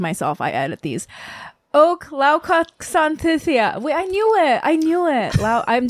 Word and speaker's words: Laokwansathitaya: Laokwansathitaya. – But there's a TH in there, but it's not pronounced TH, it's, right? – Yeah, the H is silent.